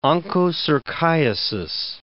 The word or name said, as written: onchocerciasis